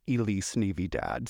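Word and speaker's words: Elise Navy-Dad: Oh my God, please follow me on Instagram and Venmo and Cash App at Elise Navy Dad, spelled like it sounds. That is Elise, like the white girl name, Navy, like the dark blue color, and Dad, like the man I never met.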